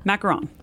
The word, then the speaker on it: macaron: How do you pronounce that? – Macaron. – Is that the same thing as what Hoosiers call macaroons? – Well, most people call macarons macaroons, but we're okay.